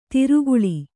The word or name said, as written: ಧಾರೆಗಾಳೆ